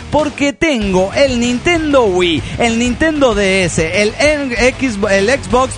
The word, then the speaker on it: Wii: Paz y amor y una carga de sueños. Bueno, esperemos que lo pueda cumplir. – Sí, post-scriptum. El Wii se pronuncia así, Wii, y no Y.